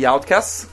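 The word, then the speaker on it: OutKast: Questi sono gli Outcast. La sto pronunciando bene stavolta? – Stavolta perfetto, non c'è nessun difetto. – E ci ascoltiamo. – Perfetto, nessun difetto.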